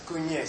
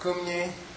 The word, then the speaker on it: kunye: The first one is kunye. Say kunye? – Kunye. – Which is sometimes mispronounced kumye, and that's a kind of massage.